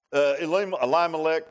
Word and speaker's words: Elimelech: Elimelech, I think it's how you pronounce it, Eli and Melech, means my God is king.